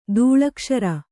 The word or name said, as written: ದೂಳಕ್ಷರ